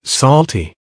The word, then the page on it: salty: American English